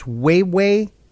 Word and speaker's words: Huawei: I believe the company is pronounced Huawei. It's spelled H-U-A-W-E-I.